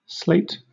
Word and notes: slate: British English

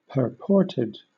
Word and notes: purported: British English

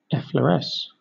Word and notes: effloresce: British English

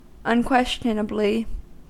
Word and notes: unquestionably: American English

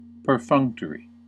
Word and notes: perfunctory: American English

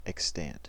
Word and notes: extant: American English

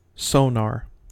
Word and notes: sonar: American English

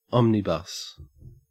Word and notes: omnibus: Australian English

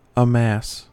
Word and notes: amass: American English

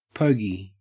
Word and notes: pogey: Australian English

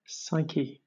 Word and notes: psyche: British English

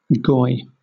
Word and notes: goy: British English

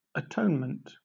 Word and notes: atonement: British English

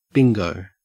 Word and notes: bingo: Australian English

